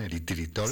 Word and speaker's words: eritritol: Es que no sé si lo digo bien, eritritol. – Sí, sí, está bien dicho.